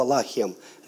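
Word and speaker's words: лахем (לָחַם): Лехем и слово лахем. Лехем и лахем. Это два слова разных, но они пишутся одинаково, но произносятся по-разному. Лехем, лахем.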